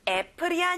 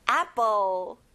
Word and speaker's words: apple: 여러분, 애플이 아니에요. 입을 크게 벌려서 아로 발음해야 돼요. apple.